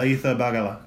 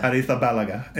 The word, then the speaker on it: Arrizabalaga: Arrizabalaga. – Balaga. Yeah. – Arrizabalaga.